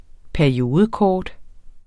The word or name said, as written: periodekort